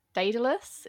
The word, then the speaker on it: Daedalus: Oh, Daedalus? Is that how you say it? – Yes, yeah, yeah.